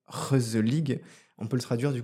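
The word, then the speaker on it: gezellig: Mon mot de cette année, c'est Geselig, ça s'écrit, et ça se prononce Reuselig.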